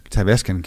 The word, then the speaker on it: Tavascan: Eller Tavascan. Jeg ved ikke, hvordan man udtaler det. – Nej, Tavascan siger jeg. – Vi siger Tavascan.